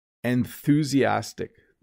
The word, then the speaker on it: enthusiastic: It looks hard to pronounce. Let me say it a few more times. Enthusiastic. That TH can kind of trip you up a little bit.